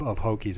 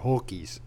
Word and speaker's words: Hokies: It was a bizarre pronunciation of Hokies. It was more Hulkies. So just pronounce it correctly and stay away from the VaTech.